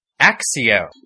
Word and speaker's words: Accio: And there is still debate about this, but in that, and the narrator asks you to pronounce it or you can't keep going with the game, is Axio.